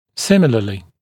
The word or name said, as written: similarly